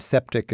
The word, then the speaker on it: septic: And septic is, of course, the short for septic tank, which rhymes with yank.